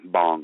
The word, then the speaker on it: bhang: And bong is B-A-H-N-G, is that, or B-A-N-G, or? – Yeah, it's spelled B-H-A-N-G, bong. – B-H-A-N-G.